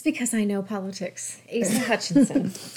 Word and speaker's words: Asa Hutchinson: Let me see. Asa Hutchinson. – Is you went you went with Asa? Asa, yeah.